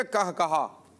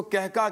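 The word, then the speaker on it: قہقہہ: نماز میں کہا کہا لگانا، آپ نے تلفظ بھی ادا کیا کہا کہا، ورنہ تو اس کو کہکا کہتے ہیں، کہکا. تو اصل درست تلفظ پیارے بچے یہی ہے، کیا کرنا؟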